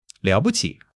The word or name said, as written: liao3 bu5 qi3